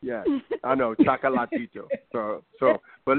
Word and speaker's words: Chocolatito: Yeah, Chocolito. – Right. – I'm saying the name right? Because I'm terrible with names. So it's Chocolatito. – Chocolatito.